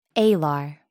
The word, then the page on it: alar: American English